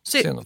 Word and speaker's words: Xenofon: De grekiska historieskrivarna Herodotos och eh, Xenofon, uttalar man det så? Xenofon. – Xenofon. Xenofon. – KS.